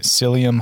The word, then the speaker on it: psyllium: Psyllium husk? – Yeah, that's right.